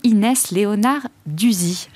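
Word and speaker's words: Inès Leonarduzzi: Je suis ravie euh, d'accueillir aujourd'hui Inès Léonard Duzy. J'ai bien prononcé Tout à fait.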